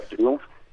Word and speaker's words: Triumph: Mira, correctamente en México le podemos decir Triumph, porque pues la Real Academia dice que lo puedes pronunciar como se escribe, okay. Sería Triumph. Los ingleses le dicen Triumph.